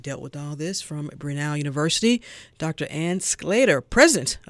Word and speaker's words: Brenau: Yes, it is Brenau.